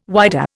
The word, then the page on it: whydah: Indian